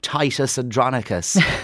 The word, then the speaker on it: Titus Andronicus: They simply responded, Titus Andronicus, which is, if you do not know, Claire, renowned as the most violent of William Shakespeare's play.